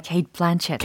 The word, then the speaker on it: Kate Blanchett: Yeah, Kate Blanchett.